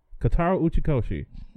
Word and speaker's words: Kotaro Uchikoshi: Okay, I am gonna butcher this name. Kotaro Uchikoshi. Kotaro Uchikoshi. – That wasn't too bad.